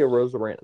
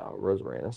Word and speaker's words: Arozarena: Gosh, dude, how do you say his name? A Rose Arena? – Randy Arozarena. That's how I think. Yeah, Arozarena sounds right.